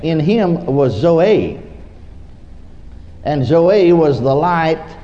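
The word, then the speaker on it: Zoe (ζωή): This one in these particular verses is spelled Z-O-E, pronounced Zoe.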